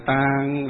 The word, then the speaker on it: tạng: Tạng. – Đó, cái chữ tạng nói trầm, nó trầm xuống một chút.